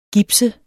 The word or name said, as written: gips